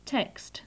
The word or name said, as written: text